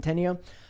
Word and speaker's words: Centineo: And then they advertise who's in the movie, which is Pierce Brosnan, Aldis Hodge, Sarah Shahi, and Noah Centineo. Is that how you pronounce Centineo? – It? – Centineo.